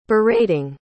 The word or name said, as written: berating